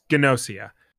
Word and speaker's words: Gnosia: Yeah, there's a G in. There's a hard G. That's how the narrator was saying it. – Yes, Gnosia.